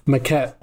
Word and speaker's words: Maquette: So I'll start with Maquette. Maquette. I don't know how you pronounce it. But this game was Maquette. Yeah. – Maquette.